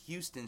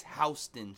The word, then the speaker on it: Houston: But if you're in New York, there's a Houston Street, but it's not Houston, it's Houston Street.